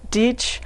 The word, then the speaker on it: Dietz: But first I want to say that my name is Jean Dietz, and Dietz rhymes with teach, and it's spelled like diet school.